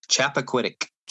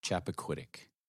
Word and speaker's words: Chappaquiddick: And Chappaquiddick. – Chappaquiddick.